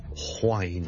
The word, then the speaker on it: whine: 其实如果你学某一啲 accent 咧，系的确咧系会读 whine 咁样嘅。例如爱尔兰嘅英文咧，好多时候咧都会听到。 Wine W-I-N-E 同埋 W-H-I-N-E 發音係唔同，聽住第一個係 wine wine wine wine wine，第二個係 wine wine wine wine wine，聽落去你嘅感覺咧，佢當佢好似 H 音之前跟住先加個 wine 咁，wine and wine，咁就唔係錯唔錯嘅問題，而係唔同地方口音。